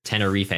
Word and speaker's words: Tenerife: We also have some young defenders in James Sands, Miles Robinson, Shaq Moore, who plays for Tenerife. I hope I'm pronouncing that right. – Tenerife, of course, of the Segunda Division in Spain.